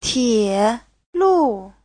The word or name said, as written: tie3lu4